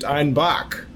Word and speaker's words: Einbeck: Bock originated in southern Germany, specifically in the Munich area, in a little town called Einbeck. But the Bavarian accent, they pronounced it Einbach.